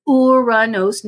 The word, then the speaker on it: Uranus: Okay, I'm saying it properly just to avoid all the jokes because everybody loves to joke about that name, but it's pronounced Uranus, named after the Greek god of lightning.